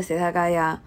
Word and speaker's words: 世田谷: 接下来一种派系叫做 setagayake，这个，嗯，它没有翻译成中文，但是我查一下，这个 setagaya 有一点那种谐音梗的意思，因为，呃，东京有一个区叫世田谷区，也是这个读音是一样的。